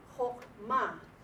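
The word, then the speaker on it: Chokma (חכמה): Because this syllable here, ho, ma, you notice when I say it, Chokma. The accent is on the last syllable.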